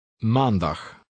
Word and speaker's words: Maandag: Regular word stress in Dutch falls on the second to last syllable, which in this case is man. – Read 11. – Maandag.